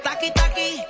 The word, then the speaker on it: Taki Taki: Taki taki, taki taki rumba.